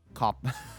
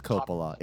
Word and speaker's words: Coppola: Coppola. – It is Coppola.